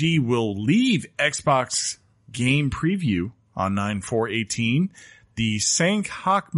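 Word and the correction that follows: Sanhok: map will come... I don't know if I'm saying that right. – Sand Hawk.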